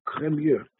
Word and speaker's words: Cremieux: His name was not really Karmi, even though that's the way it's usually pronounced in the yeshiva world. He was from France, uh, 18th uh, century France, and uh, he was the Rav of the town of Cremieux.